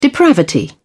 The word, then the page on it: depravity: British English